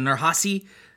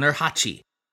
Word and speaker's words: Nurhachi: I've heard it pronounced both with a soft C, as in Nurhasi, and with a hard CH, as in Nurhachi. And given that the second is more in line with the Mandarin transliteration, and also the pronunciation used by the villainous Lao Tzu in Indiana Jones and the Temple of Doom, that's the one I'm going to go with, but your mileage may vary.